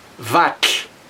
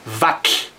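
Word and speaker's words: vatlh: So if you have 10, it is wa Wa-Mach, or 50 is waar mach For 100, you use vak. Vak. The syllable vak ends with this difficult sound TLH. We spell it TLH because it's a T and an L at the same time. So it is Wack.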